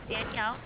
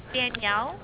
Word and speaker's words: Danielle: My name's Danielle. – Okay. – L-T-2-3-4-7. – All right. What was your email? Sorry. – Danielle. – Okay. And how did you spell it? D-A-N-I-E-L-L-E.